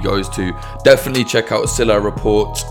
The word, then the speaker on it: Silah: And I just want to apologize for constantly saying Salah when I meant to say Silah, but, you know, I pronounced it wrong quite a lot.